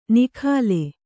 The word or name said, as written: ನಿಖಾಲೆ